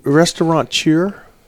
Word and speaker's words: restaurateur: And restaurateur, is that how you say it? – Restaurateur.